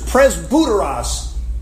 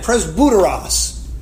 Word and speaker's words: presbyteros: That's the Greek word for elders, presbyteros. Let me say it again, presbyteros. Okay, you're thinking to yourself, that word rings a bell in my mind. Presbyteros. That sort of sounds like the word Presbyterian, doesn't it?